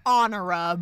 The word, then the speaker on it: Honora: So, playing Pauline Parker's mother, Honora? – It's Honora. – Honora Parker. – It's spelled Honora, but it's Honora.